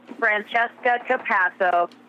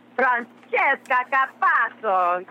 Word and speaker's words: Francesca Capasso: Exactly the way I would pronounce it. Francesca Capasso. Of course, my Italian relatives would say, and, you know, my Italian friends would say, Francesca Capasso. So, when I introduce myself, I pronounce it just like you do.